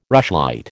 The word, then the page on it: rushlight: American English